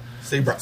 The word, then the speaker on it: Saibra: the Doctor starts emphasizing the bruh. – Sabra.